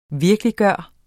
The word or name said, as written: VIRKELIGGJORTE